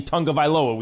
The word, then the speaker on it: Tagovailoa: No one wants to say Tagovailoa because even though we've been told 15 times, it's actually Tagovailoa. We see the spelling and we don't believe it.